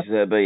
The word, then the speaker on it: De Zerbi: So De Zerbi, isn't it? Pronounce that right? He's the manager? – De Zerbi, yeah.